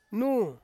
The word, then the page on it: nu: Ansus